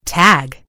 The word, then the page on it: tag: British English